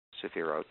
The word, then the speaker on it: sephirot: Each one is called a sephira, spelled S-E-P-H-I-R-A-H. The plural of that is sephirot, and it has an O-T-H on the end. S-E-P-H-I-R-O-T-H, and it's pronounced that way. The accent is on the last syllable, sephira and sephirot.